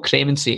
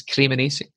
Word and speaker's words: Cremonese: I keep saying it wrong. It's not Cremonese, it's Cremonese.